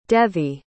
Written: devi

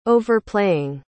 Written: overplaying

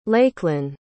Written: Lakelyn

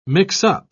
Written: mix-up

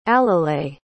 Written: alile

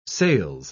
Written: sales

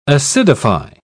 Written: acidify

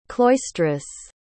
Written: cloistress

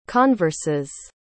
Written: converses

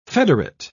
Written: federate

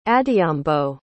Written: adhiambo

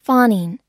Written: fawning

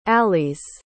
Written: alis

